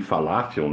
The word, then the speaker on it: falafel: Já o Falafel apresenta uma versão vegana com bolinho de falafel, ou de falafel, né, que é a pronúncia certa, salada fresca e molho artesanal.